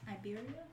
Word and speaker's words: Iberia: Am I saying that right? Iberia? – Iberia? – Yeah, it's like Siberia.